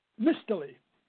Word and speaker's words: Mistele: Is it William Mistele? – Mistele.